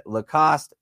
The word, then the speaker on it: Lacoste: You can fine-tune your attributes and earn new coaches, new fittings, and gear from Adidas, Head, Lacoste, and New Balance. Is that how you say Lacoste? – Yes, that's exactly how you say it.